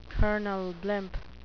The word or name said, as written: Colonel Blimp